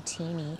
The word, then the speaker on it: Fotini: And there's three of them on there, and it's the way they've pronounced it is Fotini. – Fotini. – Yeah, not, what do we say? Fotini. – Fotini. Fotini. Yeah, Fotini. That would make sense.